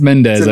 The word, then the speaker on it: Mendes: Is that how I say it? Did I say it wrong? – That's Mendes, I'm pretty sure.